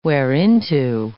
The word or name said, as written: whereinto